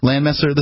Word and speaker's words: Landmesser: This Lochmesher guy, am I getting his name right? – Mark Edge would pronounce it August Landmesser. – Landmesser, this Landmesser fella.